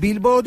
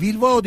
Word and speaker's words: Bilbao: Bu arada Bilbao değil Vilvao diye okunuyormuş.